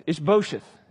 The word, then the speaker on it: Ish-bosheth: Ish-bosheth is how you say it.